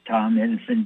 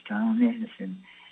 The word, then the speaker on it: Tom Edison: It's Tom Edison Press. That's right. This is your host, Tom Edison. Uh, T-O-M-E-D-I-S-O-N, kind of like that uh, old-time inventor of the electric light bulb.